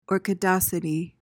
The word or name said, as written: orchidacity